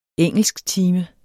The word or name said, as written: engelsktime